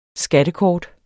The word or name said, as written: skattekort